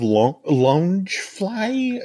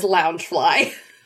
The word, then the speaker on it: Loungefly: Well, yeah, but what was interesting, I noticed a lot of lounge fly. Is that how we pronounce it? – Everybody in my circle says lounge fly.